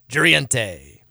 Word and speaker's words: Jurriente: J-U-R-R-I-E-N-T-E. Juriente.